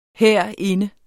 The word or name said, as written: herinde